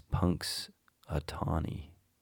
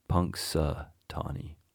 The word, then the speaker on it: Punxsutawney: I always think it's Punk. Is a Tony, Punxsutawney, but it's Punxsutawney, Punxsutawney.